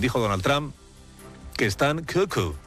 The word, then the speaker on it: cuckoo: En inglés también, solo que allí la sílaba acentuada es la primera y por eso dicen cuckoo.